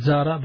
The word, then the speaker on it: Zahra: Okay, and is there a name like Zahra, Z-A-R-A? – It's Zahra, but people pronounce the name and altered it from time to time, and they pronounce it Zahra, but it's Zahra, the proper pronunciation, Z-A-H-R-A, and it means flower and flourishing, and it was a title given to Sayyida Fatima, radiallahu anha.